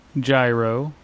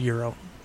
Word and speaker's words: gyro: And what they went for were the flavors of the Greek Town Gyro. – Okay. Euro. – Huh? – Euro? – It's spelled Euro, or pronounced Euro.